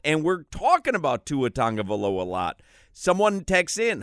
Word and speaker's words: Tagovailoa: He explains how to pronounce his name, and it is with an N, even though there's no N in there. It is pronounced Tagovailoa.